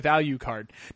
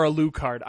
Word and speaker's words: Alucard: But anyway, I think it was also compelling because I think for most of us, this was the first run-in with Alucard. Now, granted, he was a playable character or a Lucard. I call him Alucard because it just pronounces better.